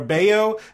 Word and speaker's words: Bello: And then it's it's B-R-A-Y-A-N for Brian. It's B-R-A-Y-A-N. It's Bello, or Bayo is B-E-L-L-O.